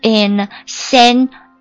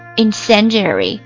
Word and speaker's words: incendiary: 那这个就是里面提取出来的。这个单词呢读作 incendiary, incendiary, incendiary, I-N-C-E-N-D-I-A-R-Y, incendiary, 它是一个形容词，表示放火的，字面意思的，它还可以表示煽动性的。我们来分别看例句，比如说喷火器 ,an incendiary device, 燃烧弹 ,an incendiary bomb, 火攻 ,an incendiary attack.